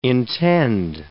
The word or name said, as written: intend